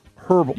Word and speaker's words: herbal: It happens to be like the Cajun focuses on peppery flavors, while the Creole is more about the herbal. I say it wrong. Herbal. – Herbal. – Herbal. – You just eat the H. Herbal. – Okay.